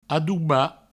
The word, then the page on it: adobar: adouba